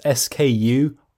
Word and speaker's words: SKUify: Now, you might be wondering, hold on, how do I say that? So it's SKUIFY, SKUIFY.